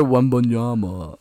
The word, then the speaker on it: Wembanyama: So Victor Wembanyama.